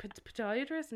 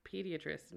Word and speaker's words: podiatrist: I was like, podiatrist? And you were like, pediatrist. And I was like, okay. – No. – I. – What? I think you still said it wrong there. Pediatrist? No. – Podiatrist. Podiatrist.